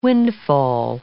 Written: windfall

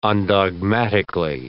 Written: undogmatically

